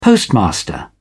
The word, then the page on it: postmaster: British English